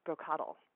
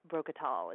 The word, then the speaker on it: Brocoddle: And since you were struggling with our little broccoli gnome guy's name, it's actually pronounced Brocoddle. That's Brocoddle. Although I did kind of like when you were saying Brocatal.